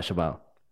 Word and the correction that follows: cheval: Oh, duh. Cheval.